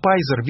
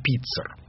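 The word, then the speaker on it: Pizer: По-английски его фамилия произносится как Пайзер, видимо, он Пиццер. P-I-Z, да?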